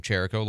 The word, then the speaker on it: Cherico: That is how you say his name, Cherico, like Jericho the city with a CH, and we had a chance to talk with them on Thursday.